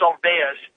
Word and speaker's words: Olweus: Having said that, the ALWEUS program, which is it's spelled ALWEUS, O-L-W-E-U-S, it's pronounced ALWEUS, but it is about the entire school culture.